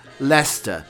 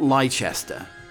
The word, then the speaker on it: Leicester: And the worst of all, it's Leicester, not Leicester.